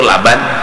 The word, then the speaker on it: laban: Asyaddu bayadan minal labani, itu laban, labani bacanya laban, bilanya labin bata. Ya Allah. Membarang aja bacaannya aja labin kah laban kah, salah baubah maris baubah makna. Labin bata, laban susu.